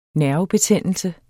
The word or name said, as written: nervebetændelse